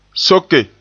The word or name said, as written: soke